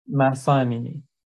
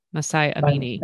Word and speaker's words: Mahsa Amini: Mahsa Amini. – Okay. Mahsa Amini.